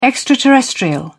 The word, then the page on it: extraterrestrial: British English